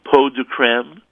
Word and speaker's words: peau de crème: She starts off with, how do you pronounce this word? Peau de crème, or if you want to get really technical, peau de crème, and then you have to insert a phlegmy, back-of-the-throat, crackly French sound.